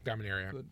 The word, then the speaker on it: Dominaria: Dominaria. – It's coming out. – Yeah. – Did I say that? I said that right.